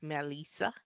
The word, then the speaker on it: Melissa: She told me I was pronouncing her name wrong. It's Melissa.